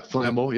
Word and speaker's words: Flambeau: Is it Flambeau? – Yeah, Flambeau, yeah.